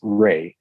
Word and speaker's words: Rea: My last name's Ray, and it is spelled R-E-A.